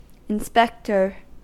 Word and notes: inspector: American English

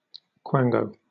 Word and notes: quango: British English

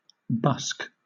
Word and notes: busk: British English

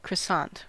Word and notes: croissant: American English

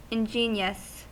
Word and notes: ingenious: American English

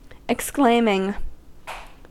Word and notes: exclaiming: American English

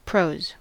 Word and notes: prose: American English

